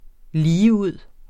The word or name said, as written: ligeud